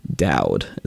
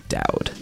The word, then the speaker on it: Daoud: Daoud is what we're going with. D-A-O-U-D. Daoud. – Yeah, Daoud. This is right.